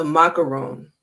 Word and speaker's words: Macaron: France is the Moroccan. Or, oh, no, I said that wrong. The Macaron.